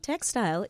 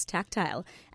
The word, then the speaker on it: tactile: Tactile, and it's not textile, it's tactile. And it is spelled T-A-C-T-I-L-E. That is T-A-C-T-I-L-E, tactile.